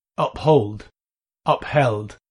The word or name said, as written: uphold